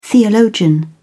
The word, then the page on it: theologian: British English